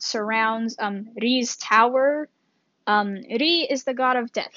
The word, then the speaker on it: Ri: His name is spelled R I, pronounced Ri, not Rai. He is not a loaf of bread.